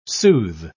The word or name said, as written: soothe